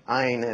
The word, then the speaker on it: Ayn: Ayn is how she pronounced her name. And a lot of people call her Ayn Rand. It's supposed to be Ayn Rand.